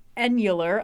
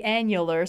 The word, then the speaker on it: annular: I've been told it's pronounced the annular solar eclipse. You see, the problem is annular would be like annulment, which is a different word, even though it's spelled the fucking same.